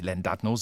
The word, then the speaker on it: Llandudno: Der hat ein Doppel-L am Anfang, aber er heißt gar nicht Llandudno, sondern Llandudno.